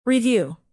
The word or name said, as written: review